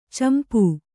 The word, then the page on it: ಚಂಪೂ: kannada; campū